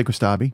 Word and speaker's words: Kostabi: Kostabi. How would you say it? – I would say Kostabi.